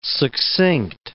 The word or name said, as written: succinct